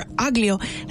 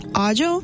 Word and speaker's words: aglio: And I've learned that garlic was written as aglio, A-G-L-I-O. So I was like, oh yeah, I'm looking for aglio. And they were looking at me like I was crazy. And I was like, aglio? It's pronounced agio.